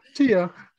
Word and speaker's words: Tiha: My name is spelled really odd, but the H is silent. It's just Tiha.